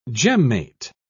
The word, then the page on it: gemmate: British English